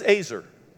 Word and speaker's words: ezer: And that word is Azer. Okay? E Z E R, but pronounced A Y hyphen Z E R. Azer.